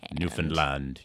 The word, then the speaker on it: Newfoundland: I think you just got to emphasize the land. – Newfoundland. Yeah, you're right. If you emphasize the land, you get it pretty good.